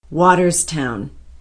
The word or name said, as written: watterstown